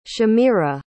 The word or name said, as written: shamira